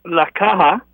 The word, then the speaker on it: La Caja: But I bet you that This La China, how do you say it? – La Caja. – La Caja. – C-A-J-A, yeah. The okay, C-A-J-A.